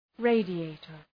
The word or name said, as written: radiator